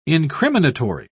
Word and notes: incriminatory: British English

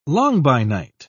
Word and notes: langbeinite: British English

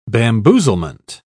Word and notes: bamboozlement: British English

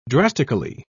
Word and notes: drastically: British English

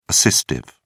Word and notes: assistive: British English

